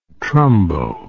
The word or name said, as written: trumbo